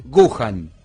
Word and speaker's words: Wuhan: Entonces este, miramos y puse Buenos Aires eh, y la ciudad encima más complicada que es eh, Wuhan. digo, lo estoy pronunciando bien. – Wuhan. Wuhan, lo pronunciaba mal yo.